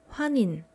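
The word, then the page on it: 환인: Hwanin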